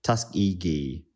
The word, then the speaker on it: Tuskegee: This is something that we mispronounced once, and a lot of people corrected us. And I'm like, Tuskegee.